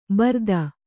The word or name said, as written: ಭರಿತ